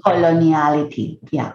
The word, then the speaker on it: coloniality: I also think it speaks to the colonization of our language, you know, and having decolonization versus, is it coloniality? Am I saying that right? – Coloniality, yeah.